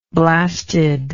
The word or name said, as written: blasted